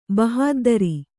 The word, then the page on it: ಬಹಾದ್ದರಿ: bahāddari; Kannada